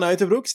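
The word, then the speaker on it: Uijtdebroeks: Kian Uttebroeks, that's how you, I think, properly pronounce it. Just wanted to throw that in there, because it's completely different to what you said.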